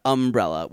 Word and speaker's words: umbrella: Say umbrella with an accent on the first syllable. It's how I was brought up.